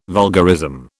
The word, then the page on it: vulgarism: British English